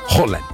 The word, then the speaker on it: Haaland: Y la doble A es Haaland. Haaland. Así es.